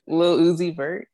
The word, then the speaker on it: Lil Uzi Vert: Lil Yeezy Vert, which I could say his name all fucking wrong. – Lil Uzi Vert? – Yeah, okay.